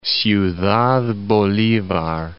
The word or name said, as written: Ciudad Bolivar